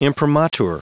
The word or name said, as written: imprimatur